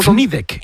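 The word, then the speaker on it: Fnideq: Pues mira, usan sobre todo Aragak, Marruecos, y otra que dice Fndek, que es un poco Fnidek, Fnidek. – Es un poco complicado de pronunciar.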